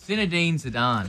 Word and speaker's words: Zinedine Zidane: It's Zinedine Zidane.